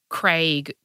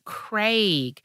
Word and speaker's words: Craig: And it's like you say to an American, how do you say Greg? And they go Greg. And you, how do you say Craig? And they go Craig. It's like, I'm sorry. Mm. Those two don't rhyme. You are weird.